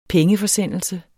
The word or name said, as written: pengeforsendelse